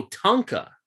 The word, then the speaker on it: Tunka: T-U-N-K-A. – Oh, Tunka.